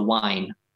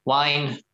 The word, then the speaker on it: waen: It's called the wine. It's not spelled the same as a bottle of wine. But it's a Welsh word. I'm not even sure what the translation into English is, but wine, W-A-E-N.